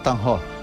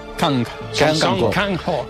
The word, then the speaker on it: Kang-ho: Song Tan-ho. – Kang-ho.